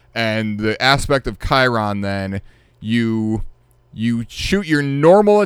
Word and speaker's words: Chiron: Charon. Like, no, it's not Charon. – Okay. – It's Chiron.